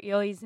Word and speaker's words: Ely: First, even though we looked it up, we still pronounced Jennifer Ely's name wrong. Fair warning, we pre-recorded all of the episodes covering the 95 version, so we're going to say Eel every time. Apologies in advance. It's Ely, not Eel.